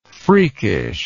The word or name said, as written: freakishness